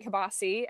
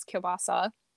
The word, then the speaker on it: kielbasa: I'm sorry, I said it wrong. We always called it kielbasa as a kid, but it's kielbasa.